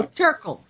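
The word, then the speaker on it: Terkel: I think it was one of your American authors named Studs Turtle. Studs Turtle. I think I've got his name right. No, Turkle. Turkle. With a C. – Yeah. With a K.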